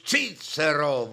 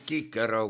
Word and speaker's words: Cicero: oli Marcus Tullius Cicero või Cicero, kuidas keegi tahab seda ladina keelt hääldada.